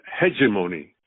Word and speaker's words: hegemony: There is a word, if I pronounce it correctly, it's hegemony.